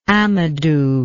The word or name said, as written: amadou